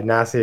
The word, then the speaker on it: Nasi: Yeah, Nasi is my online handle.